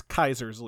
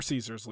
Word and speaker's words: Caesar: Kaiser, or Caesar, it's pronounced differently throughout the game, though I think outside the Legion, they usually refer to it as Caesar. Within the Legion, they call it Kaiser.